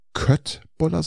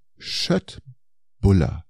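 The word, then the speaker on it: Köttbullar: Und äh, das heißt nicht Köttbuller, sondern Schöttbuller. Das ist Schöttbuller. Äh, also das schwedische Wort für Fleisch wird Schött ausgesprochen. Okay. – Und äh, das schwedische Wort für Brötchen, Buller. – Hm. – Und darum klingt es die korrekte Aussprache eigentlich nur Schöttboller.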